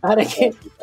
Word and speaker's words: Arequipa: And this is an appetizer, and that originates from Arequipa. Did I say that right? – Yeah. – Arequipa.